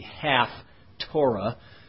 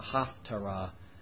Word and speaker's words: Haftarah: I'm pronouncing it somewhat incorrectly, so you know it's not the half Torah. It's not that. It's the Haftarah reading.